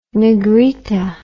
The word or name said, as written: negrita